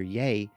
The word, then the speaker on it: Yei: Claire brings up another member of the Habitat's administration staff, according to the pronunciation guide, pronounced Dr. Ye, spelled Y-E-I.